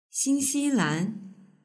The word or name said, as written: xinxilan